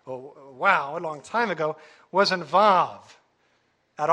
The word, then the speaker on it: Vav: This is known as the Vav, this modern-day Ashkenazi Hebrew. Now, according to Hebrew linguists, Hebrew scholars, they say the Vav was originally a wow, and pronounced as a W.